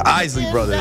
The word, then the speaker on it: Isley Brothers: I think it's pronounced the Isley Brothers? – The Isley Brothers. Yeah.